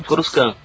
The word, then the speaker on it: Coruscant: Coruscant, o T é mudo.